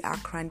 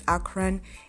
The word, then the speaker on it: Akran: So my name is Mede Akran. That is spelled M-E-D-E. Then my surname is Akran, A-K-R-A-N. So hope